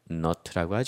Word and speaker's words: knot: 그럼 이렇게 코바늘에 실을 넣는 거, 그래서 매듭을 만드는 거를 K-N-O-T, 거기 어원프레드 나왔죠. knot라고 하죠. 이것도 K는 이제는 없어지고 knot, 그럼 매듭이라는 뜻이 됩니다.